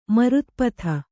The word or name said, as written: ಮಾರುತಪಥ